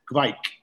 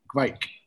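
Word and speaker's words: kveik: And I said to I said to Patrick and Noy, how do you pronounce it? And they say he says Gveik, Gveik, like that.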